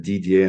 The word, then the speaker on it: Didier: For most of my American friends, I need to stop it at Didier and say it's like written DJ, because that's as far as it can go.